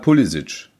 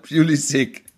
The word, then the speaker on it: Pulisic: Auch da sagt der Spieler, klar, ich habe kroatische, glaube ich, Vorfahren und die sagen halt Pulisic. So. – Mhm. – So, und wenn ich es amerikanisch ausspreche, dann heißt es halt Pulisic.